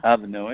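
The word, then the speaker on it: Alva Noë: Because I was also looking at this, I don't know how to pronounce the person's name, Alva Noe? – Alva Noe, yeah.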